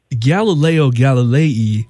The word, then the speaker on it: Galileo Galilei: But Galileo Galilei, did I say that right for the first? – You did.